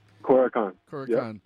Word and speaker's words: Korokan: Korokan, am I saying that right? – Korokan. – Yep.